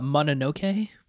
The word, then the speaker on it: Mononoke: Mononoke? – Mononoke.